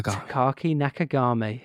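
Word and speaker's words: Takaaki Nakagami: Takaki Nakagami. – Takaki Nakagami.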